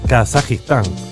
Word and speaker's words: Kazajistán: ¿Cómo se dice? ¿Eh? Kazajistán. Eso. Así, señores.